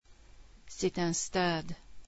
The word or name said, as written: stade